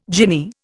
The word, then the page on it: jinnee: Indian